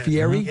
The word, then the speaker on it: Fieri: Is that the way you pronounce his name? Is it Fieri? – Uh-huh. Yes.